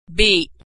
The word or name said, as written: beat